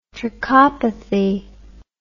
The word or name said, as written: trichopathy